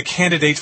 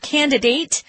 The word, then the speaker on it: candidate: Who's going to be the candidate for the Greens? – I wanted to mention there are two pronunciations for this word, candidate or candidate. They're both correct. You'll hear both in the U.S.